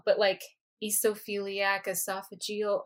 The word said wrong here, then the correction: eosinophilic esophagitis: Okay, I'm sure I didn't do it right. Help me out, Kaitlin. – You're close. It's eosinophilic esophagitis.